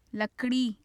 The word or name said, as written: lakri